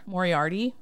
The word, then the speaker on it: Moriarty: And so she shows a pic of his partner, Moriarty.